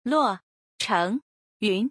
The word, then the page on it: 洛城云: Luo Chengyun